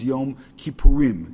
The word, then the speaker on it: Yom Kippurim: Or, actually, the way it's pronounced in the Torah all the time is Yom Kippurim. It's always said in the plural, and it's never said in the singular.